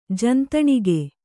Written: ಜಂತಣಿಗೆ

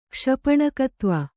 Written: ಕ್ಷಪಣಕತ್ವ

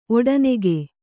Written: ಒಡೆನೂಕು

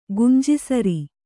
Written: ಗುಂಜಿಸರಿ

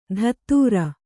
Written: ಧತ್ತೂರ

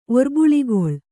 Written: ಒರ್ಬುಳಿಗೊಳ್

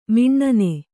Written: ಮುನ್ನಿ

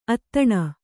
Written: ಅಧೀನೆ

